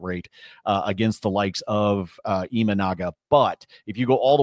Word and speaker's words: Imanaga: Did I say it right, Brad? Imanaga. – Can you? – There we go.